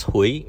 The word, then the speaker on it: Huie: First name is Gordon, last name is pronounced Hui, H-U-I-E.